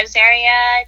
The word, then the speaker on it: Azariah: Did I say that right? Azariah? – Azariah, oh, either's good. yeah, Azariah Designs is my jewelry brand that I created.